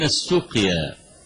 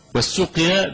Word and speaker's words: السقيا: السقيا، والسقيا بضم المهملة بعدها قاف، مكان هو على بعد يومين من المدينة، اسم مكان اسمه السقيا على بعد يومين من المدينة.